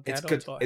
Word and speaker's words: Gadot: Is it pronounced Gal Gadot? Gal Gadot. – It's Gadot. – Gadot, right. Gal Gadot.